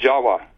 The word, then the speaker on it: Jawa: Furthermore, appending the suffix of T-I-S, the word Atlantis, is also spelled T-I-V or pronounced T-I-W. These are pronounced as W's in Dravida, such as in Java. The word in Java, the island of Java, you know, is pronounced Jawa. Actually, Indonesians will pronounce it Jawa, not Java like we do.